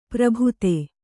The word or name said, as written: ಪರಿಭೇದ